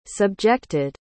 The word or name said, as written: subjected